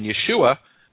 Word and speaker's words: Yeshua: And so, this is a noun that we have here, what's called a common noun, Yeshua, with the emphasis in the final syllable. It has a hey at the end, a silent hey. And then Yeshua is a name,